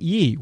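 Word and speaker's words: Yii: Or I think it's pronounced Yi. It's Y-I-I.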